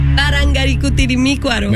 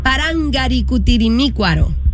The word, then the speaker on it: Parangaricutirimícuaro: Parangaricutirimícuaro. – Me la mataste ahí. – Parangaricutirimícuaro. – No, le faltó. Oh, una sílaba.